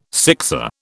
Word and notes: sixer: British English

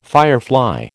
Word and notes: firefly: American English

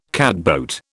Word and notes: catboat: British English

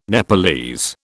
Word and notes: Nepalese: British English

